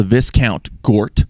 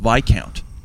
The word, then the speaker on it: Viscount: I stayed at a hotel called the Viscount Gort. And then I found out on the last day it's actually pronounced Viscount.